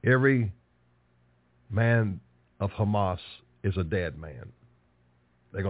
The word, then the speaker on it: Hamas: By the way, I think it's 2554 or 2555, and the Strong Exhaustive Concordance, it's spelled C-H-A-M-A-S. In the Hebrew, it means violence. Hamas. Violence. The transliteration is the C is silent. C-H-A-M-A-S. They don't use the C. You just hear them say Hamas.